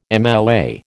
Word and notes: MLA: American English

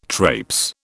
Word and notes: traipse: British English

